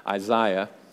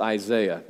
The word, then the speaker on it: Isaiah: And he loves that fact that at times I slip into that British Isaiah. But it's actually pronounced Isaiah. It should be everywhere in the world, actually, pronounced Isaiah.